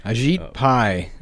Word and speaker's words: Ajit Pai: Ajit Pai.